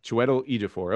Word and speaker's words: Chiwetel Ejiofor: Chiwetel Ejiofor? – Chiwetel Ejiofor? – Chiwetel Ejiofor.